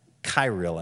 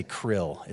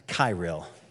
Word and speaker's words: Kirill: If you want to put that picture up of Putin, and this is Patriarch Kirill. I think that's how they pronounce it. I keep wanting to say krill. It's not krill.